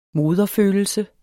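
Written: moderfølelse

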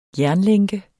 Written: jernlænke

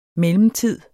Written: mellemtid

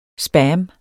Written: spam

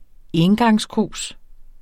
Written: engangskrus

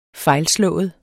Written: fejlslået

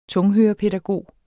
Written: tunghørepædagog

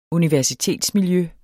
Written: universitetsmiljø

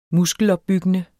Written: muskelopbyggende

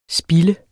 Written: Spild

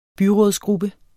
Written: byrådsgruppe

